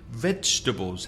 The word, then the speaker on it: vegetables: Vegetables. The first syllable is stressed. Vegetables. Meat and vegetables. Of course, the mistake many Spanish speakers make is to put 4 syllables in that word. Vegetables. No, it's vegetables.